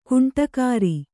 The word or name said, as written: ಗೂಂಡಾಗಿರಿ